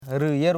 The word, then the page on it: rilje: ril.je; Koro